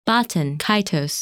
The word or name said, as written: Baten Kaitos